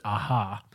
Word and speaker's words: Aja: He said, it's a long story, but here in Spain we pronounce it Aha, or Aha, A-H-A.